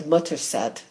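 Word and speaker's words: Mothershed: There's no history of any Modesitt leaving England, but there is history of mother sheds and they, they pronounce it in England Mutterset. They don't pronounce the H.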